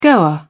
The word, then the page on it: goer: British English